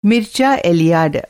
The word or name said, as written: मीरचे एलिएड